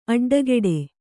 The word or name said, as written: ಅಡ್ಡಕಟ್ಟು